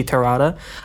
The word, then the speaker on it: Terada: My name is Suichi Terada. I've gotten a few questions early on the beat on how to say my name, so that is it, Suichi Terada.